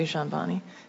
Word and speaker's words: Jahanbani: I wanted to follow up, first of all, particularly with Secretary Kennedy and Mr. Dajan. Can you pronounce it for me? I apologize. Just I want to make sure I get it right. – Jahanbani, thank you. – Silent D, thank you.